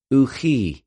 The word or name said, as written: οὐχι